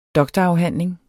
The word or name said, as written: doktorafhandling